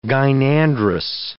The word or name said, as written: gynandrous